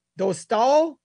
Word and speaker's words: Dostal: and they have Dostal. I think I said it right? I think for the first time in a year, I've said his name right on the first try.